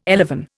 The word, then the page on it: elevon: Indian